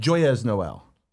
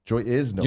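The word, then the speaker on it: Joyeux Noël: Joyeuse Noël. – Joy is Noël. – You're not. You're pronouncing all the letters. – I'm doing it all wrong there, though. We need again. – Joe E. Noël.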